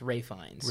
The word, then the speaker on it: Ralph Fiennes: No, it's not Ralph... It's Ray Fiennes. – Ray Fiennes. – It's not Ralph Fiennes. I pronounced it Ralph Fiennes for years, and someone's like...